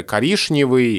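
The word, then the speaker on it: коричневый: Я бы рассказал, что произношение «шн» на месте чина, а раньше было гораздо более распространено. И колоссальное количество слов, которые мы сейчас произносим через чина, произносилось раньше через шина, и все вот эти знаменитые примеры коришневый и грешневый, и все такое прочее. И постепенно под влиянием орфографии произношение шн уходит. Приходит произношение чн, то есть как написано.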